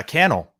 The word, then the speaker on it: Cannell: Super grateful to be here, fired up, and it's uh Cannell rhymes with YouTube channel, so we're good to go.